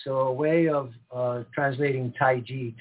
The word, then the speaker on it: Tai Chi: And the correct pronunciation is Tai Chi, as if it were a J, but the energy that we're harnessing is called Qi.